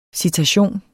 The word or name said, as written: CITATIONENS